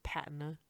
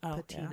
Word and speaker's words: patina: The one that hit me, we've talked about this one, Patina, or it's like my audiobook called said Patina instead of Patina. – Yeah. – And that one, we looked up and we're like, oh, it's the American version is... Patina, and the British pronunciation is Patina.